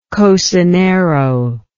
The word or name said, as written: cocinero